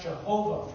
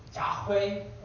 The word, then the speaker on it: Yahweh: The name of God, it's sometimes pronounced Jehovah from the Hebrew, but it's probably more accurately pronounced Yahweh. We're not 100% sure.